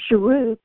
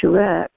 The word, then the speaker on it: Shuruk: And a Shuruk, I've never heard it pronounced Shurek, but that's what they wrote. A Shuruk has a U sound.